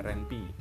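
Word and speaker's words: Ren'Py: kemudian oh RenPy, bacanya RenPy, kemudian Ink dan apa ya rata-rata sih paling umum itu menggunakan bahasa seperti Python dan RenPy ya.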